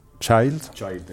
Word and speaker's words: Schwarzschild: Después aquí empieza a hablar de este señor que no sé cómo se pronuncia, Schwarzschild. – Schwarzschild, es decir, sí.